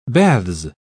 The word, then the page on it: baths: British English